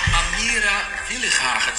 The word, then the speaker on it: Amira Willighagen: Hallo, Amira Willighagen, zeg ik dat goed? – Ja.